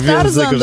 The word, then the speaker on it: Tarzan: A to nasz Tarzan dobry. – To Tarzan, że niby RZ, ale nie czyta się jako Rzy, tylko Rzy. – Tylko Rzy. – Ta Rzy An.